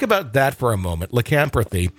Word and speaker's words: lycanthropy: And if I can say this correctly, I'm horrible at words, lycanthropy, am I saying that right? Lycanthropy? – Lycanthropy, yeah. – Okay, there we go. – You got it.